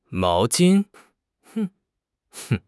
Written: mao2 jin1

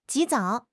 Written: 及早

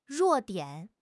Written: 弱点

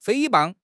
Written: 诽谤

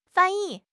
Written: fan1 yi4

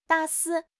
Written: 大肆

